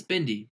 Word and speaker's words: Bindi: Bindi? – It's Bindi.